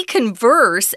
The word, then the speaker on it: converse: Um, when I see converse, and that's the verb pronunciation, I think of the uh, old shoe line.